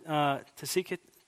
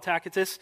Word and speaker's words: Tacitus: And to seek Tacitus, some pronounce it to seek it. I'll call it Tacitus.